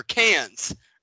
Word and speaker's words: Cannes: I'm going to bring up All the Love You Cons, or Cans, or how the fuck do we forget? It's Can. All the Love You Can. – The S is silent.